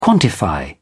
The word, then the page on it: quantify: British English